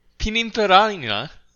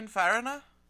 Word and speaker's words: Pininfarina: Pininfarina. – I think you had it right. – I think I skipped a syllable in Pininfarina? – There. Pininfarina?